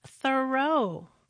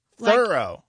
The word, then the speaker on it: Thoreau: All this time, I thought his name was pronounced Thoreau, but no. – How is it pronounced then? – Thoreau. – Thorough.